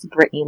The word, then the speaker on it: Brittny: Instagram is The Brittany Level. Twitter is Brittany Level. And of course, on Facebook, it's Brittany Level. And it's spelled B-R-I-T-T-N-Y. And my last name, Level, L-E-V-E-L.